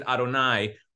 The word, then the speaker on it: Adonai: We pronounce it Adonai, which is just a way of saying my master, but we don't actually ever say the name.